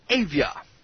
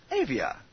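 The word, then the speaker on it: Avaya: Actually, it's called, it's spelled A-V-A-Y-A. I'm going to pronounce that Avia. Avia? Avia.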